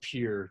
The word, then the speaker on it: Pierre: But it's pronounced Pierre.